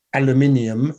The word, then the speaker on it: aluminium: So don't don't put aluminium is how you say it properly.